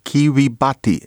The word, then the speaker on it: Kiribati: The Gilbert Islands pronounced in Austronesian. Kiribati. It's like Gilbert, Kiribati, so Kiribati. You want that, Kiribati.